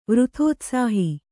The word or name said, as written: ವೃಥೋತ್ಸಾಹಿ